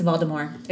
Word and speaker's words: Voldemort: And they said you do not pronounce the T at the end. That it's Voldemort. It's like you'd pronounce like the French pronunciation.